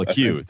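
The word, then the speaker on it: Lecque: I think it's Jalen LeCay. I forget how you pronounce his last name. – I think it is. – LeCay, thank you.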